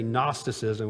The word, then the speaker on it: Gnosticism: When we say Gnosticism, we don't pronounce the G, but that's the way I've always pronounced it.